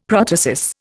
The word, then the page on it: protasis: Indian